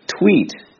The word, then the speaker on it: tweet: 另外，大家注意 tweet 中的 e 发音是长音。 Tweet, tweet. 而 Twitter 中的 i 的发音应该是短音。 Twitter, Twitter, 这两个大家发音的时候一定要区分开。